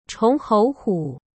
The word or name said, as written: chonghouhu